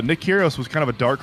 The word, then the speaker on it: Nick Kyrgios: Karen Khachanov bests Nick Kyrgios in a five-setter at U.S.